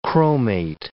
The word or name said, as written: chromate